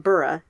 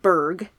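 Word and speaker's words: burgh: is actually pronounced Burra, not Berg, like I was pronouncing it as an American, and also some English cities like Leicester.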